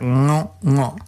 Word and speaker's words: ngo: So it's pronounced, no, it's spelled N-G-O, okay? But so we would, something like Nos, okay? That's like the end of the word sing, like and then with an O after it, so it's like Nos. That's how you say it.